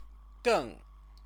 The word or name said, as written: geng